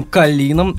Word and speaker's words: Калин: Э, Женя всем прекрасно известна по сотрудничеству с группой «Новый Львак» и с их продюсером Марком Калином. А именно так правильно произносится его имя, как Женя мне перед записью объяснила.